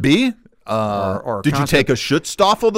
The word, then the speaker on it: Schutzstaffel: Schutzstaffel.